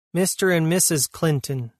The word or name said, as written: Mr and Mrs Clinton